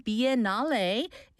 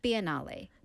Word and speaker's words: Biennale: How do you pronounce it? – Biennale. – Biennale.